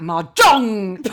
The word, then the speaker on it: Mahjong: I thought it was a silent J. – No, it's not. It it's a loud J. It's, in fact, Mahjong, like that.